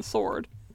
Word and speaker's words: sword: I think sworn makes more sense, because you don't pronounce the W in sword.